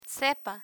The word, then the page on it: sepa: spanish